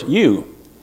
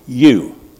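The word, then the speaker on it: eu: The word is you, E-U. It's just pronounced you, E-U.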